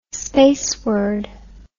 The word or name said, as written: spaceward